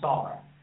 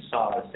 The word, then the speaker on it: Saar: Just type my name, Xavier, and actually pronounce Saar. I know it kind of sounds like Sayer, but it's actually Saar, Xavier Saar.